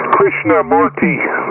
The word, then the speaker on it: Krishnamurti: By the way, the name that you were having problems with is Krishnamurti. That's what how it's pronounced.